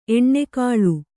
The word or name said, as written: ಎಣ್ಣೆಕಾಳು